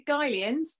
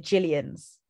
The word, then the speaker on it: Guylians: Little fact for you, or little quiz for you, Guylians. I think that's how you say it. – No, you don't. You say it Jillians. – Oh, it's spelled Guylians. – Yeah, but it's definitely not pronounced that way.